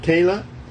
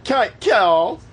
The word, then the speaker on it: Kyal: But yeah, her name is Kyle. Is this pronounced? – Kayla? – No. – K-Y-A-L. – Kayal? – K-Y-A-L. Kyle. Kyle.